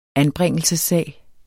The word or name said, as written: anbringelsessag